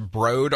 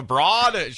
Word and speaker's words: Broad: And I want to reiterate that it's pronounced Broad Art Museum because nothing makes my wife's eyes roll more than when I say, hey, you want to check out the Broad?